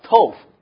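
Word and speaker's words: tov (טוב): 耶，福乐是比较更好，因为在原来的文字是 tov，我们一同来读一下 tov，像豆腐这个发音啊就对了啊，吃豆腐 tov 就是好。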